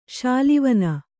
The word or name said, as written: ಶೂಲೆವೇನೆ